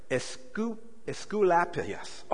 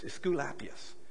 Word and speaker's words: Asclepius: I have to say that again so I get it pronounced right. Asclepius. Oh my goodness, Asclepius.